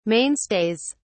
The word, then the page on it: mainstays: British English